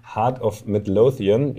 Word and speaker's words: Heart of Midlothian: Und der spielt aber jetzt noch, und das ist ja das Spannende, jetzt heute mit seinem Verein Heart of Midlothian, ich hoffe, ich habe es richtig ausgesprochen, Klang gut? – Sehr schön.